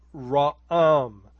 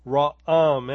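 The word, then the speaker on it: ra'am (רעם): And you could pronounce it something like ra'am, ra'am.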